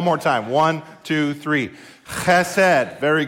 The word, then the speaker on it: chesed: There's a guttural in Hebrew where you pronounce the, CH with a lot of phlegm, like you're hawking up a loogie as you say the word chesed.